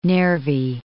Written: Nervi

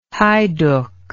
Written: heyduck

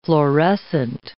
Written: florescent

